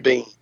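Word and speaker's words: Biehn: How do you say his last name? Is it Bean? – Michael Bean. – Bean, okay. – It's Bean.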